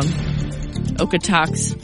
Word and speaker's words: Okotoks: So between the Goldstream Day Use parking lot all the way up to about Okotos. Okotos, I always say it wrong. Okotoks. What is it? – I think it's Okotoks. – Okotoks. Thank you.